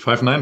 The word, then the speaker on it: Five Nine: Oder wie spricht man das Ganze aus? – Ja, ist richtig. Five Nine wird es ausgesprochen.